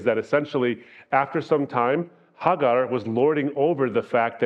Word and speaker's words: Hagar: Is that Sarah's idea was, take this woman, Hagar, who is my handmaid, Hajar is how you say it in Arabic, but Hagar is probably the proper way to say it.